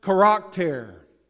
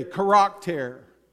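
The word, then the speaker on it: charakter (χαρακτήρ): The word image there, in the original Greek, it's pronounced charakter. That's your Greek word for the day, charakter. And it's the word that we derive our English word character from.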